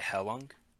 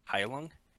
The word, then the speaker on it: Heilung: Heilung? Heilung? It's all like... – How do you spell it? – Heilung. – U-N-G.